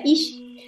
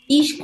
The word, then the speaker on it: Ix: Ix es SH, se pronuncia Ish, y tiene que ver Ish con el chamán,